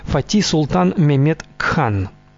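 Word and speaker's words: Fatih Sultan Mehmed Han: Его полный титул – Фатих Султан Мехмед Хан, или в турецком произношении Фати Султан Мехмед Кхан.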